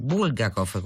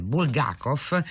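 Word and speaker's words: Bulgakov (Булгаков): C'è uno studente a Parma che dice che alla sua lettrice di russo che gli sarebbe piaciuto fare una tesi su Bulgakov, con l'accento sulla U. Lei lo bacchetta, gli dice, si dice Bulgakov, e da qui nasce l'interesse di Paolo Nori per la letteratura russa, che è anche molte volte tradotto.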